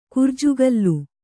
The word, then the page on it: ಕುರ್ಜುಗಲ್ಲು: kurjugallu